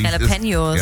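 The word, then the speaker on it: Jalapeños: Und Jalapenos, wie wird das immer richtig ausgesprochen? – Jalapenos.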